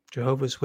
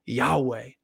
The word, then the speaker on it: Yahweh: Jehovah is a pronunciation of the unpronounceable. Scholars, same idea, same approach to the unpronounceable, but they say Yahweh.